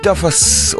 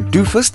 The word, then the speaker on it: Duffus: Along came Shenley Duffus, or Doofus, depending on how you pronounce it, D-U-F-F-U-S. Sincerely, the year 1972.